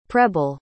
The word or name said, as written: preble